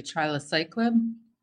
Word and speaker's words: trilaciclib: And I hope I pronounced it correctly, trilaciclib? – That's exactly right.